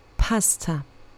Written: pasta

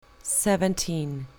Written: seventeen

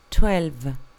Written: twelve

